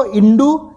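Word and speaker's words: hindú: जैसे मैं वहाँ पे बार्सिलोना में था तो वहाँ रेस्टोरेंट का नाम था ला कोमिदा हिंदू, पर हिंदू को हिंदू बोलते हैं क्योंकि एच साइलेंट है। सो LACOMIDAHINDU, लेकिन उसको बोलेंगे हिंदू बिकॉज एच स्पेनिश लैंग्वेज में साइलेंट है। सो इंडू इंदू, और इससे इंडे इंडिका, तो इसके रेफरेंसेस आपको हजारों साल पहले भी मिल जाएंगे।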